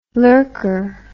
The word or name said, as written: lurker